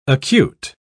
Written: acute

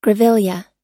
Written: grevillea

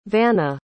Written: vanna

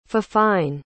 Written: fifine

